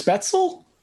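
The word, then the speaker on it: Spätzle: Spatzel, bing, ba-ding-ding-ding, spatzel, bing, ba-ding-a-little. I don't even know that's how you say it. – I think it's spetzel. – Spetzel.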